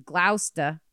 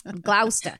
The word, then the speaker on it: Gloucester: At the time, one of our big new menu items was fresh haddock we had shipped in from Gloucester every day. That's how you say it, right? Mm-hmm. I want to say Gloucester, but I don't want to get murdered by a Bostonian. Gloucester.